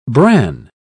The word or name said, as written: bran